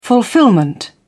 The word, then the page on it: fulfilment: British English